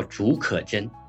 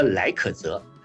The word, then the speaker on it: 竺可桢: 但是呢，这个语文老师呢，他把我们有国家有个气象学家叫竺可桢三个字，那个语文老师竟然念成了来可泽。